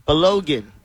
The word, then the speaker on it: Balogun: Balogun.